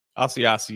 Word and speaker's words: Asiasi: and I think that felt like that Asiaki could, if that's not, help me there. Is that how you say it? – Asiasi, yes.